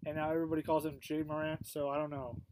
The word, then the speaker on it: Ja Morant: I keep calling him Ja Morant, but it's really Jay Morant. Without a Y.